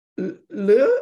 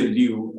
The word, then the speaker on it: Lew: Is it Le or Liu or? – Well, I say Liu, just like you'd say a book. It's a new book, so it's a new book by Liu.